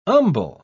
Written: umbel

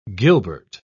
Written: gilbert

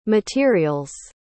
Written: materials

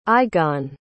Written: Igone

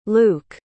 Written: Luke

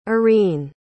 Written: arene

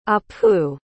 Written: appu

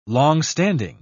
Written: long-standing

